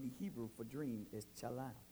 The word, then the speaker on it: chalam: In the Hebrew, the word dream is chalam, which is spelled chet, lamet, final, mem.